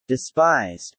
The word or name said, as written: despised